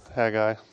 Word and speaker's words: Haggai: You may prefer the pronunciation Haggai. That's fine. However you like to say it in your head, and I'll say it Haggai.